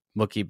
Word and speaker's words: Mookie: I would like to just take a moment to pay homage to the fact that you say Mr. Betts's first name as if it rhymes with Wookie, like from Star Wars. Mookie Betts.